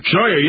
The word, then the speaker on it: Scheuer: And also Michael Scheuer. Is that how you pronounce his last name? – Scheuer, yes.